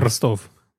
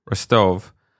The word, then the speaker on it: Rostov: Rostov. – Rostov.